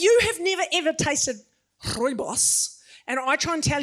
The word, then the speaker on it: rooibos: One of my favorite herbal teas is the rooibos. Did I say it right, all the South Africans? Oh, okay, I failed. Rooibos, right, you got to roll the R.